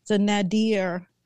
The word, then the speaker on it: nadir: I was there in the nadir. Is that the how you pronounce that word? – Nadir? Yes, sure.